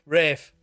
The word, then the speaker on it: Ralph: Ralph Fiennes is the worst because it's spelled Ralph. It's fucking Ralph. Rafe. Ralph.